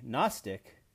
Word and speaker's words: gnostic: A gnostic, with a silent G in the beginning, believes that not only is there a God, but we, the Gnostics, have special knowledge about him.